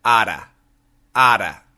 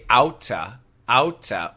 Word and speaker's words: out of: O-U-T-O-F. However, when Americans say this really fast, we pronounce it like this, ada, ada. And the spelling changes to O-U-T-T-A. But we usually do not say outa, outa. We say ada, ada. So out of the blue, B-L-U-E, like a blue sky, we say, out of the blue, out of the blue, out of the blue, out of the blue.